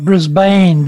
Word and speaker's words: Brisbane: He is a 6th-generation Australian born in Brisbane, Queensland. How did I do for pronunciation in Brisbane? – Beautiful. Not Brisbane. Brisbane. Okay. Nice. – Oh, yeah, it's Brisbane.